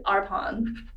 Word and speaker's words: Arpon: I pronounce it Arpon.